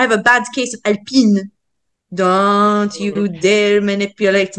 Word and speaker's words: Alpine: Isn't, isn't Alpine like a breakfast? – Yes. In fact, this is pronounced Alpine because they're French.